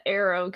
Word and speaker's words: Aero: And this our Jedi is named Kina Arrow Good. – Yeah, okay. – That's a great one. – But I want you to know Arrow is spelled like air, like A-E-R-O. Not like arrow.